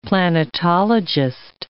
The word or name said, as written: planetologist